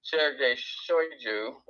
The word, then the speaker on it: Sergei Shoigu: And I don't know if that's true or not, but the man responsible for the invasion, Minister of Defense Sergei Shoigu, that's the right pronunciation,